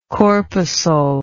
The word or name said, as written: corpuscularity